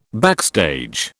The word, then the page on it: backstage: British English